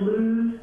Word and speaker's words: Luz: And it is Luz, L-U-Z, Smenk, S-M-E-E-N-K.